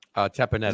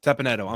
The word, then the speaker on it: Teppanyaki: And we went to, correct me if I pronounce it wrong, is it Teppanyaki? – Teppanyaki. – Is that how you say it? Teppanyaki, I'm sorry.